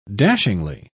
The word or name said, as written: dashingly